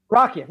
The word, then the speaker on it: Rakija: Rakia, yeah.